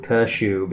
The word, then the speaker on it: Kashub: Oh, it's like a schwa here. Kashub. – Kashub, yep.